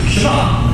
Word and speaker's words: Shema: Now, the first word of the Shema is hear or listen, which in Hebrew is pronounced Shema.